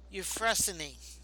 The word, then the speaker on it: Euphrosyne: That she was Euphrosyne. I hope I pronounced that right. – I think that's right.